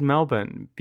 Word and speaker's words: Melbourne: Yes, I said Melbourne because that's how you say it. That R, not unlike my queer rage, is sometimes silent.